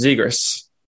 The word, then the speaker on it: Zegras: Zegras? – I've been saying it wrong, apparently. It's Zegras.